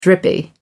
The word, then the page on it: drippy: American English